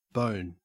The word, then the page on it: bone: Australian English